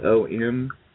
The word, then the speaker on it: om: it's spelled O-M, and you pronounce it OM.